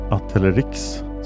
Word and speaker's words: Atelerix: Ett släkte är Atelerexis, förekommer huvudsakligen i Afrika och är nära släkt med arterna i Erinaceus, bland Atelerix, så uttalas det, förekommer afrikansk pygmé och eh, Atelerix frontalis som lever i södra Afrika från Angola och Zimbabwe till Sydafrika.